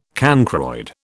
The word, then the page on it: cancroid: British English